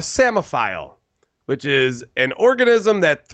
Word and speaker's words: psammophile: Samophile. – Samophile. S. I was going to say A. Was it an A? – No. – Oh, okay. Then no. – It's a P. P-S. – Oh, fuck. – P-S-A-M-M-O-P-H-I-L-E.